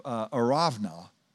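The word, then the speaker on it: Araunah: And it says, the angel of the Lord was by, at that moment, the threshing floor, okay, of Arovna, that's how you pronounce that, the Jebusite.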